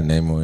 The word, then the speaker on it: Namor: our new character as Namor. Is that how we're supposed to pronounce it? – Yeah, comics is Namor, right. – Yeah, Namor, yeah.